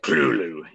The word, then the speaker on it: Cthulhu: He pronounced it Clulu, and he said it just as civilized like that, which sounds just a little too, you know, un-Lovecraftian, say it like that, because Lovecraft, when it, the first syllable be pronounced very guttural and very thick. So I think it should be more like Clulu, you know, kind of like that.